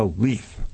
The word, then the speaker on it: Leith: Leith, L-E-I-T-H, pronounced like L-E-E-T-H, into a white supremacist compound.